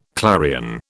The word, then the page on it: clarion: British English